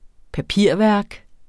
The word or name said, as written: papirværk